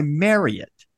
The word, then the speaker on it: Marriott: and they put in parentheses, after Courtyard By, parentheses, MARRY-ITT, drilling it into my head to pronounce this Courtyard By Marriott.